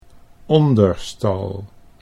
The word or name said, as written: Onderstal